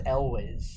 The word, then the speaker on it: Elwes: I think it's pronounced Elwes. – Elwes, right? – Yeah, that's what I keep hearing.